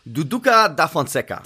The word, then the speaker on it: Duduka da Fonseca: Oder Dudu da von Fonseca. Ich weiß nicht, ob es richtig ausgesprochen ist. Kennst du den? Äh, zu zweitmal Schande über mein Haupt für heute. So peinlich, ey. Musst du mich hier so bloßstellen? Alter, kennst du den echt nicht? Nee, oder du hast den Namen komplett falsch ausgesprochen. das, will, das, will, ich auch nicht ausschließen. Nein, also Duduka da Fonseca.